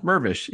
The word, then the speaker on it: Mirvish: On this episode of Adam's Corner, I'm joined by writer and director Dan Mirvish, and I hope I'm pronouncing your name correctly. We didn't go over that, but I'll let you correct me. – Close enough. Mirvish. Yeah, Dan. – Mirvish. Okay, good, Mirvish.